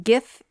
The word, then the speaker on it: GIF: Yeah, and you got to catch out that GIF. I always say that wrong. GIF. Damn it.